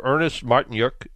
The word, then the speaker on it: Ernest Martynyuk: Uh, but we have Ernest Martynyuk from Upfix.